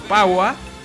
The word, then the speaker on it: Pahua: Se escribe P-A-W-H-Y-X. No, no sé. P-A-H-U-A. P-A-H-U-A. Es Pagua.